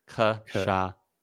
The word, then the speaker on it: Cachaça: No, wow, it's a sh and then a sa. Cachaça.